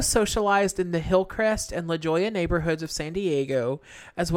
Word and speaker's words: La Jolla: La Jolla. It is La Jolla.